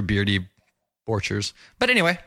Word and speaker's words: Borchers: All right, big thanks to Nat Borchers. Am I saying that right, Mindy? – Borchers.